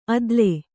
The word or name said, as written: ಅದಳ